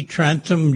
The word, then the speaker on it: Trantham: Trantham, Jr. My last name is spelled T-R-A-N-T-H-A-M.